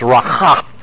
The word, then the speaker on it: raka: And he said, this is one of those words that sounds like what it does, raka. You're sort of hawking a loogie from the back of your throat to say it right.